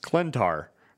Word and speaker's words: Klyntar: uh, and and Donny Cates went to Twitter and said it's pronounced Klyntar.